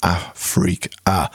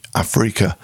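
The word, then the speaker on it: Afreaka: First up in that sequence was from 1978, from a self-titled album, Afrika. And the reason I pronounce it like that is because of the way it's spelled. It's A-F-R-E-A-K-A, Afrika.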